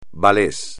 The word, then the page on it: ballet: espagnol